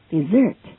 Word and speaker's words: dessert: Desert, 沙漠。那如果我们把它翻译为 desert, 背弃，背弃职责，遗弃，遗弃家人。Desert one's duty. Desert one's responsibility. Desert his family, and so on. 同时我们要注意 D-E-S-S-E-R-T, 它的发音 desert.甜品甜点 What's your favorite dessert?